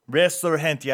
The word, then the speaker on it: Resurgente: And if you want a better pronunciation, Resurgente. – I forgot. Yes, G, the H sound.